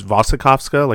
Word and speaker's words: Wasikowska: Mia Wasikowska, or I've heard her pronounce it in interviews, Wasikowska, like there's a V pronunciation as opposed to the W,